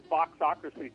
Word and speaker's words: Foxocracy: Yeah, well, I'd love to be here, and thank you for pronouncing Foxocracy correctly. It's like democracy, only Fox.